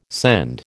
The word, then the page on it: send: American English